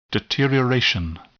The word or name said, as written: deterioration